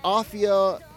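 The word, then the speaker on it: Aphia: It's pronounced Afia rhymes with mafia.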